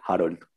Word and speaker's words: Harold: Well, my name is Harold. And, well, like the Americans say, Harold. But I say it like Harold.